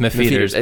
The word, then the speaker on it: M'Pheeters: Mefeeters. Mefeeters, and it's spelled M'Pheeters. – Yeah. – Which we think is pronounced Mefeeters.